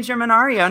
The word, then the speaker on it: Germinario: And I am Remy Germanario. And I said my name wrong. It's Ario. And yeah. I said Aria. That's so weird. Hey, I'm Remy Germanario.